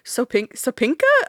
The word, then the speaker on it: Sopinka: At the John Sopinka Courthouse? – Oh, yeah.